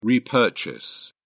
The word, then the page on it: repurchase: British English